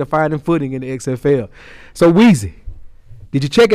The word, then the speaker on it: XFL: Because the XFL. – No, you didn't say that right. – My bad, Weezy. Give it to us. – The XFL.